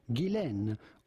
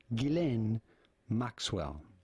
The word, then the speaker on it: Ghislaine: And in French. It can be pronounced either Ghislaine or Ghislaine. But in British, as is the case for her, her first name is generally pronounced Ghislaine Maxwell.